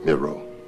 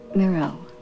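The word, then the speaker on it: Miró: Mira? – Miro. – Miro.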